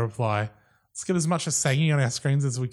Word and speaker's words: Assange: But apparently she slags off Julian Assange in the interview. – Mm. – By the way, this is how everyone should pronounce his name.